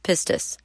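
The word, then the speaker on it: pistis (πίστις): According to Strong's exhaustive concordance, this is the Greek word pistis, which is pronounced pistis.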